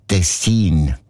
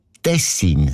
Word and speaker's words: Tessin: Also klar, Tessin. Aber der SRF-1-Hörerin Regula Stern-Grieser von Locarno ist aufgefallen, dass gewisse Leute statt Tessin Tessin sagen. Also Betonung auf mehr und mit einem kurzen I.